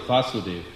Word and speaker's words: Vasudev: Krishna is Vasudev with a long A. His father is Vasudev, short A.